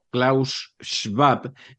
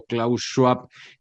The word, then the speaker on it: Klaus Schwab: Klaus Schwab, que es como se pronuncia, pero todos han acabado pronunciando Klaus Schwab, que sería la manera anglosajona de pronunciarlo.